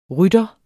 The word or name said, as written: rytter